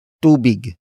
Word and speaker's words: tubig: It is the object marker that introduces what the person is giving to the beneficiary, and that is tubig. Tubig. Tubig. Tubig is spelled as T-U-B-I-G.